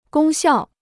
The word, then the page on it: 功效: gōng xiào